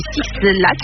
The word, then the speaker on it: Six Black: Et Six Black, oui, c'est comme ça qu'on le prononce.